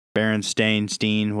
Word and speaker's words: Berenstain: Like the Berenstain Bears or the Bernstein Bears and all that. – You said it wrong twice. – The Berenstain Bears? – Berenstain, whatever.